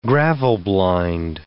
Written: gravel-blind

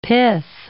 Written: pith

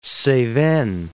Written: Cevennes

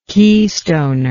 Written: keystoner

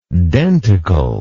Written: denticular

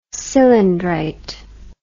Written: cylindrite

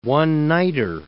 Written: one-nighter

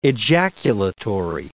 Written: ejaculatory

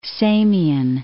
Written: Samian

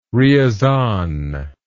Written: ryazan

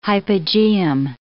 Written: hypogeum

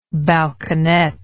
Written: balconet

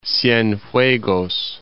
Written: Cienfuegos

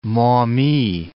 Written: Maumee

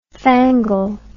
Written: fangle

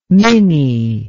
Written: minny

